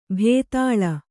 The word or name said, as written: ಭೂತಾಳ